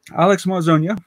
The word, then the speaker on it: Marzonia: Alex Marzonia, with the accent on the end, yeah.